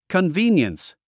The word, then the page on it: convenience: kənvíːnjəns; コンビーニエンス